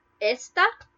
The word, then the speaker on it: esta: Esta, without an accent mark, you stress the first vowel, which is E. So it's esta, that first syllable.